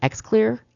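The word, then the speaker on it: Xlear: Now you can also, it's called X-Clear. I think I'm pronouncing it correctly. It's X-L-E-A-R.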